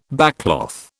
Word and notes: backcloth: British English

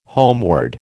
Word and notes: homeward: American English

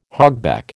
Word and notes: hogback: American English

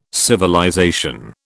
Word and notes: civilization: British English

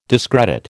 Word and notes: discredit: American English

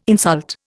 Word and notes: insult: Indian

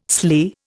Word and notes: sley: Indian

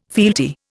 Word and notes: fealty: Indian

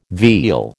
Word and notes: veal: American English

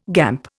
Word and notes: gamp: Indian

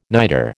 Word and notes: niter: American English

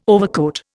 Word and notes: overcoat: Indian

